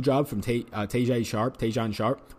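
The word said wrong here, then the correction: Tajae Sharpe: I said that guy's name like he was like Chinese or Japanese, but he's not. Tajay Sharp, I believe it is pronounced.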